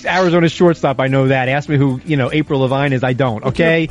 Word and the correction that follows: Avril Lavigne: Avril Lavigne. At least pronounce the name right.